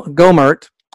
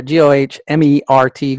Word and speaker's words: Gohmert: Go- Gohmert, I don't know if I'm saying it right, G O H M E R T, Gohmert, calls for Congress to ban Democrats due to the past position on slavery.